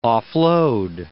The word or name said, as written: off-load